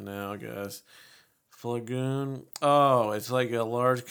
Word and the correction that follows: flagon: And it's pronounced flagoon. Let's hear what it says. – Flagon. – Oh, it's called flagon.